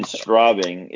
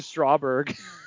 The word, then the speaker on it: Straubing: But speaking of injuries, I, I do have to bring something up with your first year in Straubing is the correct, uh, correct pronunciation there, Dave. – Straubing oh, Tigers. I, so, anyways, I said Strawberg.